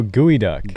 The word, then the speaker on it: geoduck: I forget how to pronounce this one. Geoduck? – Nope. – Oh, gooey duck. – Gooey duck. – I'm a fan of this. – Spelt geoduck.